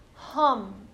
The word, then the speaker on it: hum: And one of the things that is the sound or the bija, the seed syllable for the throat chakra is hum, H-U-M, hum.